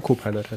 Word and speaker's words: Copilot: bei GitHub mit Copilot ähm, verwenden kann. Ich habe es richtig gesagt, ne? Copilot heißt das, oder? – Mhm. – Oder nee, doch, ja, genau.